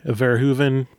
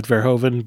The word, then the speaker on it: Verhoeven: According to IMDb, it's really Verhoeven, not Verhoeven, but it doesn't actually matter. He's Dutch, right? So we talk like Americans. – Of course we do. – So Paul Verhoeven, he's had a fantastic career.